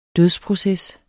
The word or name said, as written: dødsproces